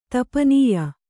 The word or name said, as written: ತಪನೀಯ